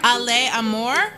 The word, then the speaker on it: Ale Amor: Ale Amor? – Yep.